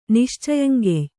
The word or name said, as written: ನಿಶ್ಚಯಂಗೆಯ್